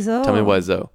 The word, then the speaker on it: Tommy Wiseau: Tommy Wiseau.